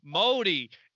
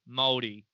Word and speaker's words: Maori: So it's pronounced Modi. – Modi. No, no. Moldy. M A O R I. Moldy. So that is the indigenous. – So there's no people. – D. – There's no D.